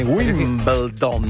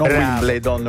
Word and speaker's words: Wimbledon: Senti come ho detto bene, Wimbledon, non Wimbledon.